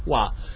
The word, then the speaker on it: vav: He pronounced it as wa, which is the Arabic pronunciation and probably the original Hebrew pronunciation.